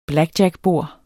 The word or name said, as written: blackjackbord